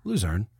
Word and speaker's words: Luzerne: Wait, I always say it wrong. How do you say it? – Luzerne. – Luzerne. – Yeah. – Luzerne. – Luzerne. – Oh, man. – We will be saying that from now on.